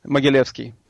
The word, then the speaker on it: Mogilevsky: Mogilevsky.